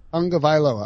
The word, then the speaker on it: Tagovailoa: Let's get, and I find it fascinating, the idea that Tua Tagovailoa. – Tonga Vailoa. – All right. Now I'm good. Now that I got that, Tonga Vailoa, I won't mispronounce it again.